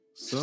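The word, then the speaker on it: Stiletto: Takže Cyril Gaius snad všichni známe, ten teď dokončil, um, ehm, teďkon dokončuje poslední ilustraci, což je loď Štyleto. Čte se to Štyleto nebo Styleto? – Styleto. – Styleto. No, no. Jo? Styleto, ok.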